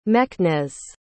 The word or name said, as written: meknes